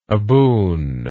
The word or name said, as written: aboon